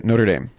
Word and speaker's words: Notre Dame: You know, with three syllables, right? Notre Dame.